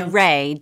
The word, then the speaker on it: Wray: Can I, very quickly, I looked up how to pronounce her last name in Hindi, and it's Ray, W-R-A-Y, pronounced like W-R-A-Y, Ray.